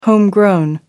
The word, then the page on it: homegrown: American English